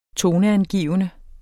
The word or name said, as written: toneangivende